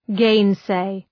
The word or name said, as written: gainsay